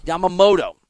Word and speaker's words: Yamamoto: Yamamoto.